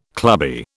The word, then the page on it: clubby: British English